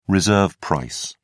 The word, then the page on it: reserve price: British English